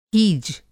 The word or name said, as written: हीज